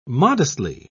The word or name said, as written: modestly